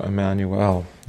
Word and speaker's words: Emmanuel: So Emmanuel. You hear the L at the end.